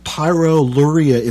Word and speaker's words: pyroluria: Mike has written a book about a condition called pyroluria infection. Mike, am I pronouncing that correctly? Pyroluria? – Yes, sir. That's right.